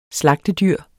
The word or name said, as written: slagtedyr